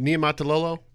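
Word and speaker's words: Niumatalolo: I think you're going to see this across the landscape and is it Niematalolo? – Yes.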